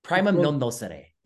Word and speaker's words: Primum Non Nocere: How do you say? Primum Non Nocere. It's Latin.